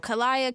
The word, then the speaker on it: Khaliyah: Khaliyah, Kayla, mostly. I get Kayla a lot. Kayla. Yeah, I get that a lot.